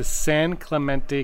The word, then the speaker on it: San Clemente: He grew up in Denver, Colorado, though, until sixth grade when he moved to San Clemente, California. San Clement? Oh. Clement. – No, you said it right.